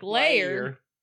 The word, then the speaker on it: Lear: As they pronounce it, they're like, Lair.